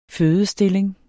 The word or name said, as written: fødestilling